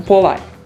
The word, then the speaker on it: polite: Lembrando que essa última sílaba dela é bem tímido, tá? Então, polite.